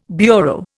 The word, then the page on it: bureau: Indian